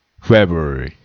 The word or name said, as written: February